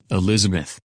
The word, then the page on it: Elizabeth: English